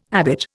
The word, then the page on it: habit: Indian